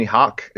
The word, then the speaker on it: Hoch: Most people just call me Hoch. It's spelled H-O-C-H because it's super German.